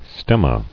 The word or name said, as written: Stemmata